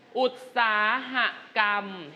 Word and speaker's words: อุตสาหกรรม: เช่นอุตสาหกรรมเห็นไหมสระอะไรอุตสาหะบวกคำว่ากรรม